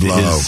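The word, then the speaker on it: Blough: was David Blau. And David Blau. – His run rhymes with cow.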